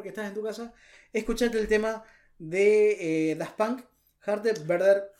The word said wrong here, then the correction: Harder, Better, Faster, Stronger: Por favor, y mi pronunciación en inglés la vamos a mejorar la próxima. Harder, better, faster, stronger.